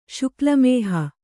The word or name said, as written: ಶುಕ್ಲಮೇಹ